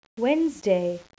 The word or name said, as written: wednesday